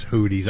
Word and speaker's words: Houthi: I think it's Houthi. – Houthi, yeah, like the blowfish.